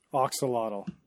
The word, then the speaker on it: axolotl: It is Mexican. You're right. But I mean, the bastardized English way is saying Oxalotl.